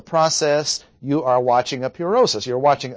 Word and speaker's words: purosis: P-U-R-O-S-I-S. Pronounce again in English, purosis.